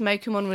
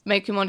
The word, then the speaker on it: Meikumon: So then when we have Daigo and Maki sort of talking about Meikumon, all I noticed is that Daigo says Meikumon really weirdly. – Yeah, Meikumon. – It's like emphasis on the ku instead of the mei. Like it's a hard ku.